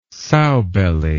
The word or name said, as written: sowbelly